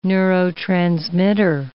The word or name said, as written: neurotransmitter